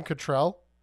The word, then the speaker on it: Cattrall: Nothing else except for Kim Cattrall.